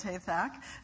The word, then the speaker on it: Hathach: But thankfully, Hathach, and I looked up how to say it, it's Hathach, the messenger, he conveys what's going on back and forth, and you had that sort of passage.